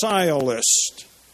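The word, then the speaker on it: sciolist: Called sialist. That's the way you pronounce it.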